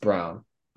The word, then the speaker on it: Braun: Also, I realize it's spelled Braun, but it's actually pronounced Brown.